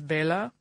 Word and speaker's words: Bela: It's because when I pronounce it correctly in the Hungarian language, which is Bela, I sound like the Count from Sesame Street.